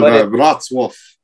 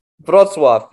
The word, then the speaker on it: Wrocław: It's spelled Wrocław. I'll put it in the chat. – Oh, yeah. – Yeah, super famous place. – And it's a... We call it Wrocław. – Wrocław, exactly.